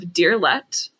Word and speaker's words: Deerlette: all going under the username of Deerlette, DEERLETTE, or Deerlette Art, spelled the same way, just add art on the end.